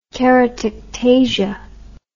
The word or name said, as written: keratectasia